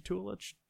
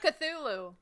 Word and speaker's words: Cthulhu: College, how do you say that? College Chutulu? Chutulu? – What? Tulu? Call of Cthulhu.